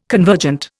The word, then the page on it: convergent: Indian